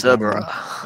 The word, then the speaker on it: Cerbera: What, how do you pronounce it again? Cerbera. I wrote it. – Cerbera. – That's why. Cerbera. – With some Blackpool, isn't it? So it's Cerbera.